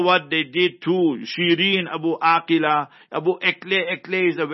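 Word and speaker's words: Abu Akleh: So Abu Akila is the proper pronunciation.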